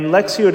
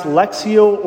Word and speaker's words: Lectio: Lectio Divina is something actually that we did last week, which was the Sunday of the Word of God.